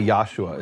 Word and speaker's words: Yahshua: And I've seen, is this correct that you spell it Y-A-H-S-H-U-A? Because a lot of Messianic people and Hebrews people will say Yeshua, and there's other forms of it. You say Yahshua.